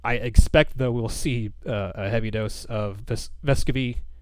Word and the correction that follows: Vescovi: excuse me, I'll say his name right, Bescovy,